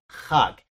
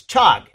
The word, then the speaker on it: chag: They in the Bible, they, I mean, I'm sorry, in the Strong's Concordance or something, they'll spell it C-H-A-G, but it's not. It's pronounced K-H-A-G, hug. If you see C-H-A-G, you're going to think it's chug, and it's not. Okay, it's hug.